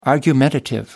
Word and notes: argumentative: American English

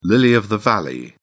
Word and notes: lily of the valley: British English

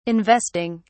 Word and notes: investing: British English

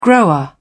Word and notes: grower: British English

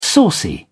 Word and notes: saucy: British English

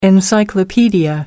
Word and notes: encyclopedia: American English

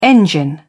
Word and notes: engine: British English

